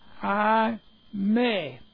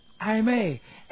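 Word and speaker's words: I may (εἰ μή): It's I may. And that's E-I-M long E. I may. As opposed to the normal word Allah, A-L-L-A, that you're very familiar with, translated but.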